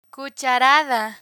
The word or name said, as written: cucharada